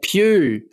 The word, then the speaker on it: Pugh: Yeah, Pugh.